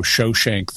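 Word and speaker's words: Shoshenq: In Egyptian records, he's known as Pharaoh Shoshank the First.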